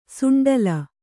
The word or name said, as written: ಸುಂಡಲು